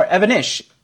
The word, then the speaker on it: Evanish: Oh, did I say his name wrong? And I really want to work with the guy even more. Evanesh. I say Evanosh. You're right. It's spelled Evanesh. Jason Evanesh. E-V-A-N-I-S-H.